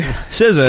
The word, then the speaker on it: SZA: It's SZA, and am I saying that correctly? – Because, yeah. – Okay, because I've like heard different variations on it. – RZA, JZA, SZA. – Okay, SZA.